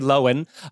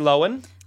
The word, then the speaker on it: Lohan: And I also said Lohan, so this is kind of double jarring for me. – Well, I mean, it's spelled L-O-H-A-N-S. So, I mean, you're not wrong to break it up. Lindsay Lohan. What am I saying that you're not saying? – You say Han, I say Han, but that could be like the gross upstate accent.